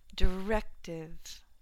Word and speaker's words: directive: Directive, directive, second syllable stress.